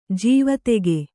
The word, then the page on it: ಜೀವತೋಕೆ: jīva tōke